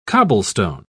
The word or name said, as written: cobblestone